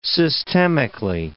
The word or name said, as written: systemically